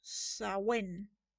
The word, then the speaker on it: Samhain: Eh, el nombre del demonio Samhain proviene del gaélico irlandés y se pronuncia correctamente en inglés como Sawen. O sea, Sa, S-A-H, Wen, W-E-N. No sé, me, encanta como dice en inglés, Samhain.